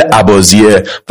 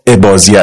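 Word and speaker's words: اباضیه: تلفظش در عمان به شکل عبازیه و در شمال آفریقا عبازیه است که هر دوش درسته.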